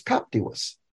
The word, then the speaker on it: captivus: I thought at first, why didn't it pronounce captivus? Well, it's because the I is long.